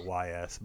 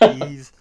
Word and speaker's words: Ys: When I was a kid, I used to just call it YS, but I believe it's pronounced Ease.